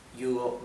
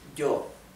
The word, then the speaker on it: yo: Double L in Spanish. Double L has a unique pronunciation, okay? Double L is pronounced same as Y in Spanish, okay? Y is pronounced G, G, okay? It's also a pronunciation that does not exist in English. So, for example, the pronoun I in Spanish is yo, yo. How do you pronounce that Y? It's like if you were unsticking the back of your tongue from your upper palate. Yo, okay, yo.